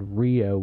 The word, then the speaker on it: Reo: We went to this town near Evansville called Rio, which is spelled R-E-O, and that's because it's in between the towns of Rockport, Evansville, and Owensboro.